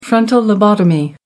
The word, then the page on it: frontal lobotomy: American English